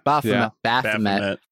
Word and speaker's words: Baphomet: We got Baphomet. Am I saying that right? – Baphomet.